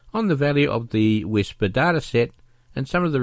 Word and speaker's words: WSPR: This Wednesday night is our February Forum Night, and it will be all about WSPR, pronounced Whisper, which stands for Weak Signal Propagation Reporter.